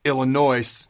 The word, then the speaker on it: Illinois: Here are my humble thoughts on the Cornhuskers opening up the 2021 season in Dublin, Ireland on August 28th versus Illinois. The S is silent.